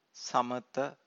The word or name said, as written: samatha